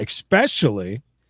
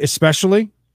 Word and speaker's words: especially: And the other thing is, too, with very little feedback, the pros are looking for people like that, especially... did I say that right? Especially? Did I say that right, Knox? – The second time. – Okay. So, especially. Not especially.